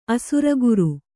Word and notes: ಅಸುರಗುರು: kannada

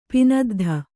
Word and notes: ಪೆದ್ದಿ: Kannada

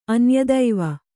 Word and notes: ಅನ್ಯದೈವ: anyadaiva

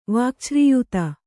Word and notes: ವಾಕ್ಛ್ರೀಯುತ: kannada

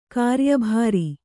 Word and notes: ಕಾರ್ಯಭರ: kāryabhara

